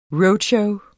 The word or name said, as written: roadshow